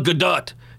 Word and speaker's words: Gadot: First of all, it's pronounced Gal Gadot, because she's Israeli, and that's how it's pronounced. She's not French. You're not waiting for Godot. It's Godot. But many people get that wrong.